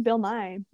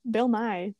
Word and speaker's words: Nighy: i think it's just bill nighy bill nighy but not the science guy Bill Nighy, like the guy from Love Actually, for living.